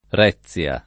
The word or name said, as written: Rezia